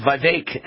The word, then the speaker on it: Vivek: And if his name is pronounced rhyming with cake, then it might be Vivek Ramaswamy, but he's never corrected me or anyone else before until last night in an interview with Hannity. He said Vivek, and Hannity said, well, why haven't you corrected me?